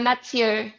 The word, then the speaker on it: Mathieu: You got your guitar player, is it pronounced Metau or I don't. – Mathieu, yeah, no, yeah, it's just French.